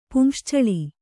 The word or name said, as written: ಪುಂಶ್ಚಳಿ